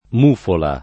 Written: mufola

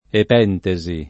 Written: epentesi

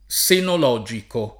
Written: senologico

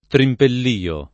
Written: trimpellio

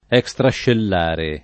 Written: extrascellare

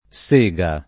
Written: sega

